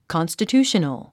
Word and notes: constitutional: kὰnstətjúːʃənl; カンスタチューショナル

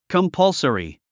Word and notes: compulsory: kəmpʌ’lsəri; コンパルソリィ

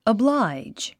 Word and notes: oblige: əbláidʒ; アブライジ

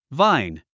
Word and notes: vine: váin; バイン

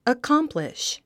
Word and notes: accomplish: əkɑ’mpliʃ; アコンプリッシュ

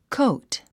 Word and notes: coat: kóut; コート

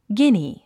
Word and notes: guinea: ɡíni; ギニィ

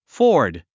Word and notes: ford: fɔ́ː r d; フォード

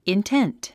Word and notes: intent: intént; インテント

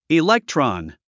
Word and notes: electron: iléktrɑn; イレェクトロォン

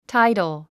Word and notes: tidal: táidəl; タイダル

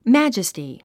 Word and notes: majesty: mǽdʒəsti; マジェスティ

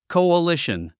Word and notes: coalition: kòuəlíʃən; コゥリシン

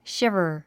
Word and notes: shiver: ʃívə r; シィバァ

